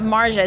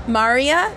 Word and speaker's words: Maarja: Maria? – Yes. – You say, excellent. Maria. – So you spell it M-A-A-R-J-A, but you say it Maria.